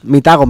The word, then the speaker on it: Mitago: ¿Cómo se pronuncia? ¿Mitago, Mitago? – Mitago, Mitago. Mitago. Está perfecto, vale. Sí, sí, sí, así es.